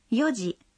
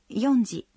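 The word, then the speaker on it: йоджи (四時): Таким же образом мы говорим «четыре часа» – «йоджи», но не «йонджи».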